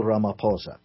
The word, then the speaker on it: Ramaphosa: Who's who of the leader of South Africa, the Cyril Ramaphosa? Is that how you pronounce it? – Let me put that in context for you. The president now is a guy called Cyril Ramaphosa.